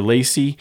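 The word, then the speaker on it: LaCie: Lacy is how you pronounce it, I think. French. I don't speak French.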